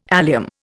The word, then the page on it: allium: Indian